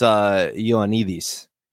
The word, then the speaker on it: Ioannidis: Oh, so I mean, I don't know how he pronounces it, but the Greek in me makes it look like it's Ioannidis.